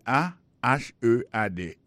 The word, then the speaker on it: ahead: Et head là c'est A H E A D. Ahead. Ahead. Go ahead. Là encore, on a point que accent tonique là tombé sous deuxième syllabe.